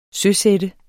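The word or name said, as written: SØSATTES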